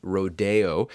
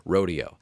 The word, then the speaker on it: Rodeo: Now, you may have heard it pronounced Rodeo, which it was fashionable at one time to use the French pronunciation of this title, and it is still completely acceptable. I will stick with the American Rodeo.